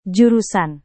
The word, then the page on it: jurusan: Indonesian